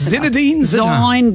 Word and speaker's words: Zinedine Zidane: Zinedine Zidane.